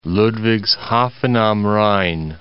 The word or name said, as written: Ludwigshafen am Rhein